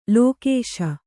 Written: ಲೋಕೇಶ

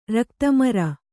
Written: ರಕ್ತಮರ

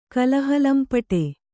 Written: ಕಲಹಲಂಪಟ

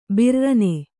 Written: ಭುರ್ರನೆ

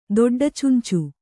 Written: ದೊಡ್ಡಚುಂಚು

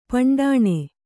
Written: ಪಂಡಾಣೆ

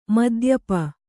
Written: ಮದ್ಯಪಿ

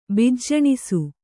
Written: ಬಿಜ್ಜಣಿಸು

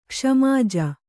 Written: ಕ್ಷ್ಮಾಜ

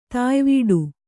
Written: ತಾಯ್ವೀಡು

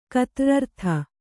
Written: ಕತ್ರ್ರರ್ಥ

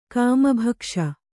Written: ಕಾಮಭಕ್ಷ

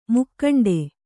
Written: ಮುಕ್ಕಂಡೆ

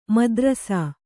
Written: ಮುದ್ರಸ

